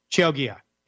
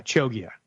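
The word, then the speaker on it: Chioggia: Cioglia Italian Beets. Cioglia. Yeah, Cioglia. That's it.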